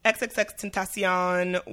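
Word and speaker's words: XXXTentacion: So XXX Tentacion was.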